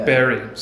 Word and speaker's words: Bury: And I'll ask a little more about Man United in a moment, but you just sort of talked about, and I know I mispronounced it, Bury. – Bury, yeah. – Bury. See, I mispronounced it. Some people say Bury. Yeah. Some people say Bury, you know. You pronounced it right.